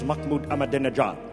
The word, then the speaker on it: Mahmoud Ahmadinejad: I am getting word that the correct pronunciation is Mahmoud Ahmadinejad.